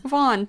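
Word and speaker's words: Vaughan: Vaughn? – Vaughn, thank you. – It's either going to be very simple, or we're going to be big idiots, and mm-hmm. it's just like, yeah, it's just Vaughn, or it's going to be something like Vaughn.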